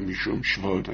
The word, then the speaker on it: mishum shvot: I pointed out in the past correct pronunciation, probably mishum shvot. Everybody says shvot. Mishum shvot.